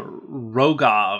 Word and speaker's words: Roga: There's a uh, Roga of Kher Keep. – You got you got to say it like you're having a hairball. It's Roga, of Kher Keep.